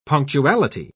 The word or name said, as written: punctuality